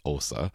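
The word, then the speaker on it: Åsa: I mean, it's spelled A-S-A, but it's got that little circle at the top of the A, so I guess it's pronounced Osa.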